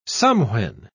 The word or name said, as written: somewhen